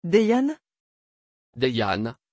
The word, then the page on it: deyan: French